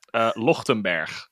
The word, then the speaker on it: Lochtenberg: Lochtenberg. – Lochtenberg? – Is there a honestly, D at the end? Honestly, pretty close. Like, you've got pretty close.